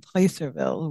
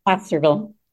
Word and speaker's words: Placerville: So it's set in, yeah, I was saying Placerville, which is not how it's said. Is could you say it again for me? – Placerville.